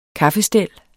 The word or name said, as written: kaffestel